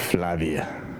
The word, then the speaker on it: Flavia: It's spelled F-L-A-V-I-A. How would you pronounce that? – Flavia. – Yeah. Mm-hmm. – I would pronounce it Flavia.